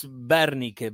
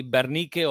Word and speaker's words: Wernicke: It's German. In fact, it's German. It's Wernicke, but in Argentina, Everybody call me Wernicke or Wernicke, because the W is different in Spanish sure.